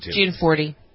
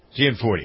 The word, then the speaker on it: Gianforte: So the fellow that won, we were talking about him yesterday, the Republican, how do you pronounce his name, Giancarlo? – Gianforte. – Gianforte.